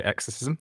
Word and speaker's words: Exercism: You pronounce it fine. So I would say Exercism.